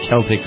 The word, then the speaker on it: Celtic: Celtic Women, C-E-L-T-I-C, like the basketball team, only pronounced Kel instead of Sel.